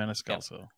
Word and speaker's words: Maniscalco: You saw Sebastian Maniscalco. Yes, or yes, how did, thank you. Manis, yeah, okay. Someone, did I say it right? Yeah. Maniscalco.